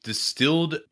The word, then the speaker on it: DSTLD: Let's take a quick break here to talk about Distilled Premium Denim. Distilled, it's actually spelled D-S-T-L-D, but pronounced distilled, has revolutionized the fashion industry.